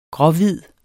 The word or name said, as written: gråhvid